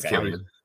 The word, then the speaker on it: Cillian: Okay, I used to say Killian, but I thought it was Cillian. – Killian. – My bad. Okay, well, I'll say it right this time then.